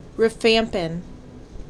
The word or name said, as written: Rifampin